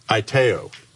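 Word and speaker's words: aiteo (αἰτέω): It's pronounced aiteo.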